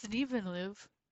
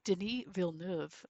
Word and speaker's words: Denis Villeneuve: It's Denis Villeneuve. Oh, I said that wrong. Denis Villeneuve, who did Dune and Arrival and the sequel to Blade Runner.